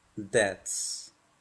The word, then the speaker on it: debts: First, I must pay my debts. Primero, first, debo, I must pagar, pay mis deudas. My debts. La letra B en la palabra debts no se pronuncia.